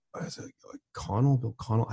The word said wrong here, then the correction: Bill Connolly: I always get his name wrong. – The SB Nation guy. – SB Nation guy. – Bill Connolly.